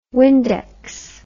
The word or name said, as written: Windex